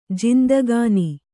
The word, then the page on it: ಜಿಂದಗಾನಿ: Kannada